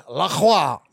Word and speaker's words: LaCroix: Yeah, but what you just did right there is you just earned yourself a sponsorship with LaCroix. – On the dot. – It rhymes with boy.